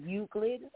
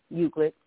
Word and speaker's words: Euclid: It's EUCLID. How do you say that? – It's actually Euclid, Ohio. Euclid.